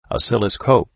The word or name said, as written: oscilloscope